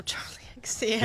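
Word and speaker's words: Charli XCX: Oh, Charlie XCX.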